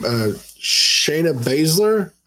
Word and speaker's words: Shayna Baszler: Shayna Baszler. Am I saying that right? – Yes.